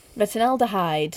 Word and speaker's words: retinaldehyde: Retinaldehyde. – Retinaldehyde.